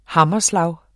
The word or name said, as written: hammerslag